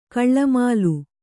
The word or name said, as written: ಕಳ್ಳಮಾಲು